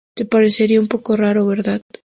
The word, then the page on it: verdad: spaans